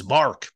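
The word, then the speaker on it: Marc: And a lot of people would call me Mike because I guess Mark and Mike sound alike. And yeah. It's Mark, but I spell it with the C, they would spell it with the K.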